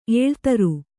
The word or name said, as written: ಎಳೆತರ್